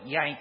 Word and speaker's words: Jank: spelled J-A-N-K, but they pronounced it Yank as if Y-A-N-K.